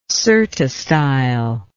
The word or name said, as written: cyrtostyle